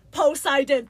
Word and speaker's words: Poseidon: There's, the name is Poseidon, three syllables. Zeus, one syllable. Hades, two syllables.